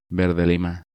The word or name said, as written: Verde lima